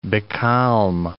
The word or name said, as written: becalm